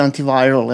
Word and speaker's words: Antiviral: filmom Antiviral uh, ili Antiviral, jel? Da. tako se izgovara, um, Kronenbergovog sina.